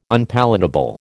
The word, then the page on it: Unpalatable: American English